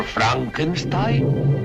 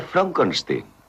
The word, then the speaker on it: Frankenstein: Doctor Frankenstein. Frankenstein. ¿Me toma el pelo? No, se pronuncia Frankenstein.